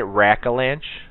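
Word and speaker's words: Rackalanche: Am I saying that right? Rack-a-lanche? – Yep. – What a great word. – R-A-C-K-A-L-A-N-C-H. Rack-a-lanche? C-H-E.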